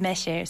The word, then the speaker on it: 매실: But if you look at the name, in Chinese they call it mei, in Japanese it's ume, and in Korean it's meshi.